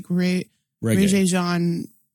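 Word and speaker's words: Regé-Jean: Also, his name's pronounced like Reggae, or something like that. – Yeah, it's like Regé Jean Page or something like that.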